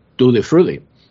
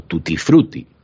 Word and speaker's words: Tutti Frutti: Bueno, si será la cosa enloquecida, que les traemos el famoso To the Fruity o tutti frutti, si ustedes quieren decirlo con una pronunciación más hispánica.